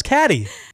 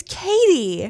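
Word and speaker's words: Cady: Lindsay Lohan's character is not Caddy. It's Katie. – I could have sworn it was Caddy. – It's spelled C-A-D-Y. I think that's what's tripping you up.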